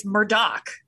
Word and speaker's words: Murdaugh: It's, well, locally, it's Murdaugh. That's the way they say it.